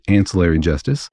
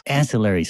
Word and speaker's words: Ancillary: I think she pronounces it Ancillary Justice. – Ancillary, sorry.